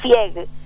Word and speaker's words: Fieg: Mi apellido es Fieg, F-I-E-G. – Fieg, yo le digo Fleg, cada vez te pongo una L en vez de la I.